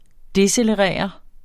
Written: decelerere

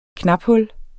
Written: knaphul

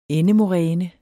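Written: ENDEMORÆNE

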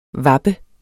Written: vappe

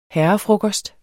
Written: herrefrokost

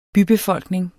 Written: bybefolkning

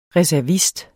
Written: reservist

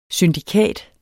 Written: syndikat

